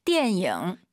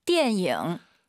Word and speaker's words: 电影: 电影. – 이 단어 참 제가 많이 가져오죠 선생님. – 是的. – 초급자분들께서 디엔잉 니은 발음으로 많이 해서 그래요. 디엔잉 잊지 마시고요. 한번 더 들어볼게요. – 电影.